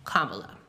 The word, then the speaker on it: Kamala: It's just like Pamela, but with a K. Kamala.